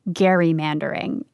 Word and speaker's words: gerrymandering: And yes, he calls gerrymandering gerrymandering. And he knows what he's doing here. Remember episode one? Gerrymandering is named after Elbridge Gerry. Reagan stands by the hard G.